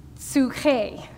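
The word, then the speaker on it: psyche: Soul, I know what it looks like, but it's pronounced psuche.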